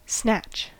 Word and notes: snatch: American English

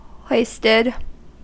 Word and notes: hoisted: American English

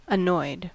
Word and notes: annoyed: American English

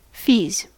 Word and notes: fees: American English

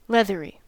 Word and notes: leathery: American English

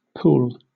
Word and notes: pool: British English

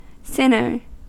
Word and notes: sinner: American English

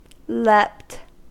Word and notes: leapt: American English

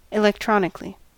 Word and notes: electronically: American English

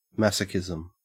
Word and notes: masochism: Australian English

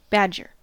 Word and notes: badger: American English

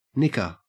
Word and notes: nicker: Australian English

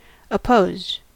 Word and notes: opposed: American English

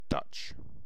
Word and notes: dutch: British English

